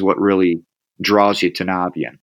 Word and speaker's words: Navien: the real correct way to say it is consider how or think about how you say the word navigate because that's really an acronym where we got it from. So uh, navigate is Navian.